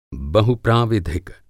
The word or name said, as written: बहुप्राविधिक